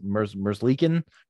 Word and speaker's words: Merzlikin: Merzlikin? – Yes.